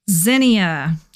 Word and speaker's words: Zinnia: Zinnia. That's the correct pronunciation of the plant we will talk about today.